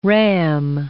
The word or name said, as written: ram